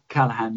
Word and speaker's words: Callaghan: Well, doesn't something similar also happen when you get the switch from Harold Wilson to, and I may be mispronouncing the name, James Callaghan or Callaghan? – That's right, Callaghan, yeah.